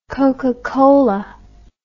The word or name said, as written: cocacola